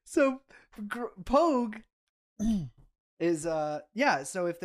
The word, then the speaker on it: Pogue: So, okay, P-O-G-U-E. That's Pogue. That'd be Pogue.